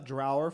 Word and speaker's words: Draugr: I'm going to call it Draugr for this part because the first and second top-rated Swedish pronunciations that I got from this random-ass website said Draugr.